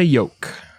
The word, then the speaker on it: Yoke: This time, he was in love with a woman named Georgiana Yolk. Old Georgiana Egg Yolk. It's spelled Y-O-K-E, not like yolk, like an egg, but it still sounds the same.